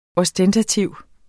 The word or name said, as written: ostentativ